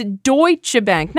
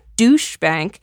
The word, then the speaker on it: Deutsche Bank: It's Deutsche Bank, not Douche Bank. Deutsche Bank has nothing to do with that kind of tacky stuff. It's the Nazi bank, Deutsche Bank, which is why it was a match made in heaven when Trump turned up at its doorstep looking for dough.